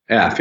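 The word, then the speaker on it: Eph: You know, the main character is pronounced F, E-P-H? – F, yeah, Ephraim.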